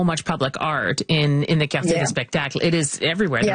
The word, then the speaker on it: Cartier: Yeah, Am I saying that right? Cartier, yeah. Spectacle.